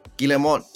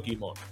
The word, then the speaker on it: Guillemot: Eh, Ubisoft fue establecida en 1986 eh, por, los cinco hermanos Guillemot, en donde Yves Guillemot, quien es el actual dueño de Ubisoft, este. – Yo creo, yo creo que he pronunciado Guimot.